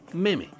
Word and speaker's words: meme: Así es, hoy vamos a estar hablando de genes y memes. De hecho, es Richard Dawkins quien acuña el término que escribimos como meme. Y no se debería pronunciar meme, sino meme. Viene directamente de mimética, viene de la imitación.